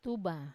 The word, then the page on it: tuba: Wamesa